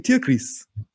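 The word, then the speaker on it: Tierkreis: It's actually Tiaklis.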